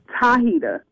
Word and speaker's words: Tahita: Tahita with a T.